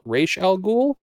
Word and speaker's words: Ra's al Ghul: Liam Neeson is the incorrectly pronounced character Ra's al Ghul in this movie. The character should be called Ra's al Ghul, and is generally depicted in other versions of Batman.